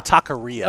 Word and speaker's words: taqueria: A tequila. – Tequila? They serve tequila? – No, it's like a taqueria. – A taqueria.